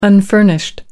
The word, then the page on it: unfurnished: American English